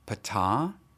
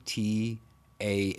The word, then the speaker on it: Ptah: Ancient Egyptian people of the Mendes worshipped Ptah. I think I'm saying that right. It's P T A H or Ta Peter.